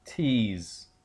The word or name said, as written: tease